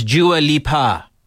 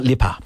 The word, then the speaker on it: Dua Lipa: I was saying her name wrong for years. It is Dua Lipa. Not Lipa.